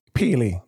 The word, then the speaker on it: Pele: Yo, dog, you know I had to tell this man last week on the podcast that Pelle dead? – Pele. It's Pele. It's Pele. – You have to say it properly.